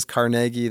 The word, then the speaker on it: Carnegie: You don't go to, but it is Carnegie. That is how Andrew Carnegie would have pronounced his name.